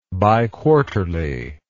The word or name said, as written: biquarterly